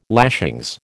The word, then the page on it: lashings: American English